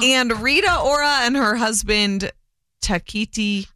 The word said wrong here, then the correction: Taika Waititi: Taika Waititi. – Mm-hmm. – Taika Waititi.